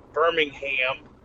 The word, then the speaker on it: Birmingham: I'm going through the Alabama city of Birmingham, is how they pronounce it down here.